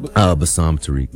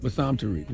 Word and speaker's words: Bassam Tariq: Bassam Tariq. – Bassam Tariq.